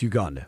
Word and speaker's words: Uganda: Is it Uganda? Uganda? – It's Uganda.